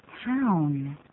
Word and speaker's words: town: 好，hometown，那个 town 的念法，有人念成好烫的烫，其实有点差别，对不对啊？ – 当然有差别，要念个 ow 的音，town， hometown，而不是烫啊，就可变成 hometown，那一听呢就是哎，这个发音还不行，所以我们再念一下。